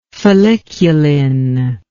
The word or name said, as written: folliculin